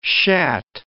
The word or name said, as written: shat